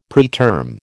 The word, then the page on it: preterm: American English